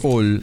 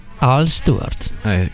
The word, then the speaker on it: Al Stewart: Io dissi all invece vedi subito, e invece una A, anche se non è una A proprio aperta, però insomma non si pronuncia certamente come all perché significa tutt'altra cosa.